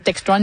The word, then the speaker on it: Textron: Oui, ben, en fait, Textron, il le prononce comme ça en anglais, évidemment.